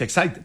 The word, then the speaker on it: Exide: se escribe Exide, se pronuncia Exide, dentro decía la...